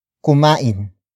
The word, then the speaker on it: kumain: Another example, ayaw nilang kumain. Ayaw nilang kumain. Ayaw nilang kumain. It means they don't want to eat, or they refuse to eat. Word for word, it's ayaw nilang kumain.